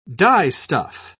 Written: dyestuff

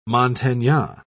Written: montagnard

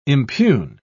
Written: impugn

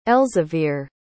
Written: elzevir

